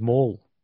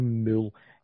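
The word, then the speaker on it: Moule: Oh, mine's Mole. So it's M-O-U-L-E. It's no Mule. Everybody keeps pronouncing my name. Even when I go to the bank and that, it's always to pronounce my name Mule.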